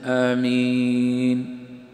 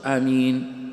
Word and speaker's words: آمين: الأول، النطق الصحيح لقول آمين، ينطقها صحيحة إما بالمد آمين أو بالقصر آمين وبتخفيف حرف الميم آمين، ما يشددها، لأن لو شددها تصبح إيش؟ آمين، تغير معناها، كانت قبل قليل إيش؟